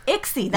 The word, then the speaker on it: ICSI: ICSI, that's how you say it.